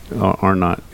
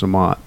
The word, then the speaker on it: Amott: I know it's Chris Amott, and I wrote it incorrectly, and that's why I pronounce it Arnott. I know it's Amott, so my fault.